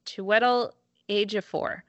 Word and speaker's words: Chiwetel Ejiofor: Chiwetel Ejiofor. – Mm-hmm. – Again, certain I'm saying his name wrong. – That's pretty good.